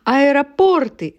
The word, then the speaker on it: аэропорты: Аэропорты. Дело в том, что если мы поставим в именительный падеж, будет слово «аэропорт». И ударение отсюда не уходит во множественном числе.